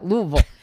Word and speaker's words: Louisville: I just think it's funny that they'll correct you when you, like, you're like Louisville. They're like, no, you didn't say it right. Louisville.